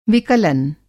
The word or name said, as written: विकलन